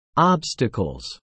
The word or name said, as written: obstacles